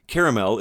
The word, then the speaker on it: caramel: For example, Meemaw would not be a fan of the way most of you pronounce the word caramel. There are two A's, but you only pronounce one.